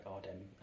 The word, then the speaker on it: Adem: But he's such a nice person that he really doesn't care if you call him Adam, but Notion, he does have this really long A at the beginning, like Adem and...